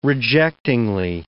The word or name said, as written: rejectingly